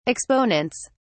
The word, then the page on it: exponents: British English